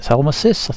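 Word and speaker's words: Salmacis: I can't resist having a bit more Genesis, and this will be the Fountain of Salmasas. Salmasis, I think I pronounced that correctly, and that's from the Nursery Crimes album, so see you after the music.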